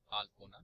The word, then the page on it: Alpona: Bengali